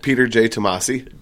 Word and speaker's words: Peter J. Tomasi: Peter J. Tomasi.